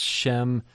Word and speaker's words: Shem: Shem uh, from the consonants in Hebrew that mean name. Shame is name in Hebrew, kind of easy to remember because it rhymes.